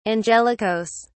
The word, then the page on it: angelicas: British English